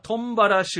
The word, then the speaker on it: トンバラ: トンバラ集落、トムハラと書いてトンバラです。